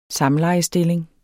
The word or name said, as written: samlejestilling